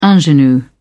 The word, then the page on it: ingenue: British English